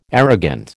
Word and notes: arrogant: American English

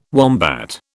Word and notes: wombat: British English

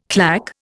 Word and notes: claque: Indian

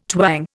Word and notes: twang: Indian

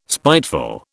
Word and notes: spiteful: British English

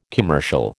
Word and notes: commercial: American English

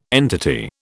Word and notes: entity: British English

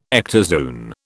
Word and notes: ectozoon: British English

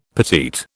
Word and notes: petite: British English

